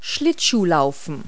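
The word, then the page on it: skate: verb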